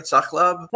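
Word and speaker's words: Sahlab: That was just immediately like, Sachlab, I can get Sachlab. And I was going to say, is it a drink or a food? But you know, am I saying it right? Sachlab? – Sachlab, yeah. Sachlab.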